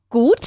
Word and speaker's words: gut: Gut. – Sí, hay que pronunciar la U un poco más larga, ¿vale? Gut.